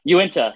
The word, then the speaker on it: Uinta: remember it by saying Uinta beer. – Uinta beer. – Are you into beer? – Yeah.